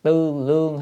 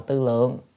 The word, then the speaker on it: tư lượng: À, tức là tư lượng, tư lương hay tư lượng cũng được, đọc tư lương, tư lượng cũng được. À, thì ở đây đọc nên đọc là tư lượng.